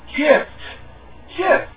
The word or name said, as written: gift